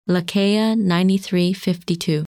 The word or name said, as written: Lacaille 9352